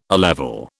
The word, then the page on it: A level: British English